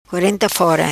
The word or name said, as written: Quarenta Fora